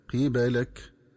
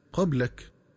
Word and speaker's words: قبالك: ينبغي التنبه للنطق الصحيح للفظ قبالك، فيقرأ بكسر القاف وفتح الباء هكذا قبالك، ومن الخطأ قراءته بفتح القاف وتسكين الباء هكذا قبلك، ومعنى قبلك أي مقبلين عليك.